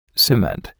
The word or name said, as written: cement